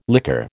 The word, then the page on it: liquor: American English